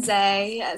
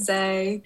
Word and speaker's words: Jai: Hi, I'm Zay, spelt J but pronounced Zay.